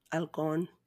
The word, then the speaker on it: Halcón: Because H is not supposed to be pronounced, right? Like in Spanish, H is not pronounced. Halcón. – I guess you're right. It's always silent.